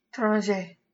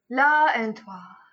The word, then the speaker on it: L'étranger: How do you say it? – L'étranger. – La and toi. – No, you're so off.